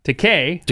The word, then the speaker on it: Takei: And by the way, on your question about how to pronounce it, it is pronounced Takei, according to George himself.